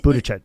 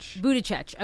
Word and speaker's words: Buttigieg: Buttigieg. – Buttigieg.